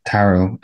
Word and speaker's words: tarot: My Italian pronunciation, it wouldn't be great, but the name that we call it, tarot, without pronouncing the T at the end, is the French version.